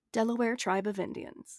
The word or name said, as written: Delaware